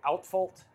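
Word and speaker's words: Uitvlugt: You'll never pronounce it the right way, but it's spelled like UITVGULT. Outfault is how you actually pronounce it.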